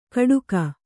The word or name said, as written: ಕಡುಗ